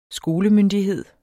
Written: skolemyndighed

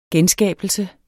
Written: genskabelse

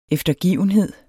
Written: eftergivenhed

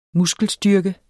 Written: muskelstyrke

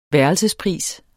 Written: værelsespris

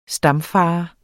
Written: stamfader

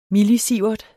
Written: millisievert

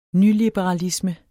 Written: nyliberalisme

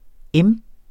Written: m.

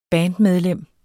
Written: bandmedlem

